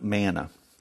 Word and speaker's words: manna: And you know, I don't know if you've ever looked up in Strong's Concordance, manna is actually pronounced as man. M-A-N, man.